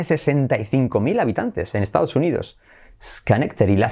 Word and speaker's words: Schenectady: Que diríamos algo así como Sneketady o una cosa similar. Pues hagan sus apuestas a ver cómo pronunciaríais esto y a ver si acertáis. Tiempo, pulsador. Se dice Schenectady. Schenectady. Schenectady. ¿Veis? La D última se hace como una R suave.